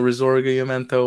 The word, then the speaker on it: Risorgimento: Okay, so Risorgimento wasn't a bad pronunciation.